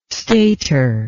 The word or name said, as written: stator